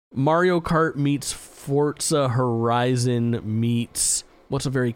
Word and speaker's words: Forza: I'm glad you're pronouncing Forza correctly. That's all I got. – Forza. – Forza.